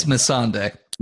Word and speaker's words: Missandei: I get that's Missandei.